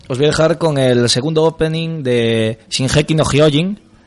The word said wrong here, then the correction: Shingeki no Kyojin: Eh, perdón, no se dice así. – Eh, Shingeki no Kyojin, os dejo con el segundo opening.